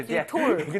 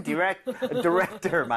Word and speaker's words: director: T-O-R. 디렉토리구나, 디렉톨. – 근데 발음은 디렉터 맞는데 너무 톨은 아니고요.